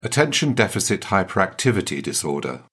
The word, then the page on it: attention deficit hyperactivity disorder: British English